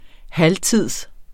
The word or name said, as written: halvtids